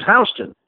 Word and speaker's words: Houston: And I know Houston is spelled Houston, but everyone in New York pronounces it Houston. And the reason for that is, you ever been to New York? That's the reason for that. People just decide 140 years ago that we're pronouncing it Houston.